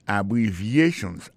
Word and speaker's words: abbreviations: Abbreviations, accent tonique là, tombé sous troisième syllabe là. Dans mot ça, il y a quatre syllabes. C'est A, bon syllabe, bri, c'est deuxième syllabe là, vie, c'est troisième syllabe là, chan, c'est quatrième syllabe là.